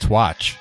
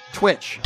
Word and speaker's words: Twitch: We're live on Twitch. What do you call it? Twitch, right? Is that what you call it? – What the hell's Twatch? – Twitch.